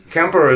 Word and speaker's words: Quimper: Kemper is spelled Camper, Q-U-I-M-P-E-R in French, but K-E-M-P-E-R in Breton.